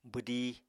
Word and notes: bwdí: apatani

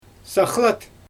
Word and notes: saxlat: Xyzyl

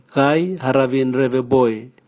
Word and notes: gai haravin reveboe: Raga

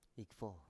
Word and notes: ikfo: Biak

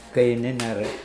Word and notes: keininere: Mokilese

